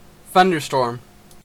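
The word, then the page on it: thunderstorm: Canadian English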